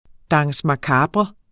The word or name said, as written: danse